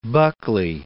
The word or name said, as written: Buckley